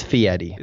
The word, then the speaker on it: Fieri: It's Fieri. – Is it Fieri? – It's pronounced Fieri, yeah. – Okay. Guy Fieri. I thought it was Ars in his name. – It is. F-I-E-R-I, but it's pronounced Fieri.